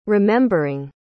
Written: remembering

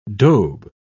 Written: daube

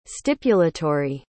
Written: stipulatory

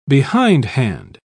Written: behindhand

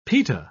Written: pita

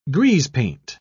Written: greasepaint

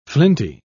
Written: flinty

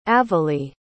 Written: avalee

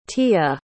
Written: tiya

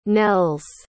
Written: nels

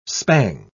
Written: spang

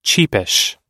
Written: cheapish

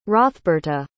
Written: hrothberta

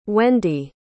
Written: wendi

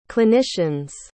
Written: clinicians

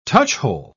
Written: touchhole

